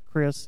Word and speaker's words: Chris: That's Chris, C-H-R-I-S. Last name is spelled C-O-B-L-E-R.